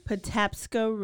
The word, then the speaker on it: Patapsco: Patapsco River. – Patapsco River.